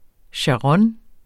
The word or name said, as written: sharon